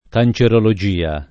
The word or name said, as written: cancerologia